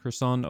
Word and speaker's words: Kherson: So that in the first few days of March, Russia claimed the land in Kherson Oblast, so very early on. – And I really hope I said that correctly. – That's the pronunciation.